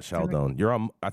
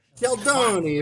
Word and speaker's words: Scialdone: Did I say this right? Scaglione? Scaldone. Sheldon. I think you're on mute, Dr. Mark. – Italian green onion. – Yes, Dr. Mark. – Sheldon. – Sheldon. Fuck. – Sheldoni, if you want to say it like an Italian. Sheldoni.